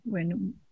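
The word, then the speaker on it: venerunt: Except what happens to the I-U-N-T? What happens to the I-U-N-T? – So Wenerunt. – Wenerunt. – Wenerunt, to drop the I.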